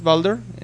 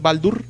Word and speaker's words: Baldur: Tiene a sus dos hermanos, que uno es Balder, que en, no sé por qué todos en nórdico se pronuncian con U. En inglés es Balder, en español también Balder, en nórdico es Baldur y Thor también es Tur.